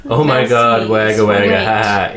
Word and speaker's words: Wagga Wagga: Apparently, we said wagga wagga wrong. – Well, of course. How do you say it? – Nader, but it is spelt like Wagga Wagga, but apparently it's Wagga Wagga.